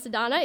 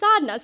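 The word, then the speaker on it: Sadhana: Sadhna, you can't do this anymore. You have to tell people how to start pronouncing your name correctly. Your name is not pronounced Sadhna, it is pronounced Sadhna, so you better do something about this.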